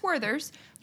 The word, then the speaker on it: Werther's: Like, wait, how do you say it? Werther's? – What's it? Gold box. – Werther's? – No. Yes, it's Werther's.